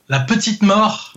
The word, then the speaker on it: La Petite Mort: How do you say that in French again? A petit mot? – What do you say? Sorry? – Petit mot? – La Petite Mort.